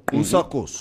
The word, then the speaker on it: Usakos: I passed Usakos. No, Usakos. That's the correct. – Usakos. – That's the correct pronunciation.